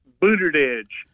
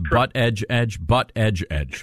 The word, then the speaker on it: Buttigieg: It would be Pete Buttigieg before it would be Mike Pence. – It's Buttigieg. We want to pronounce the man's name correctly.